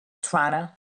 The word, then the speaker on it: Toronto: Or I think as Canadians pronounce it, Toronto. Toronto.